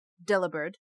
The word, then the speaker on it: Delibird: So your little language fact is that pronouncing Delibird with the same inflection as delivered yields a very similar pronunciation. So instead of Delibird, Delibird.